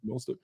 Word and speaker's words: Mostert: Mostert.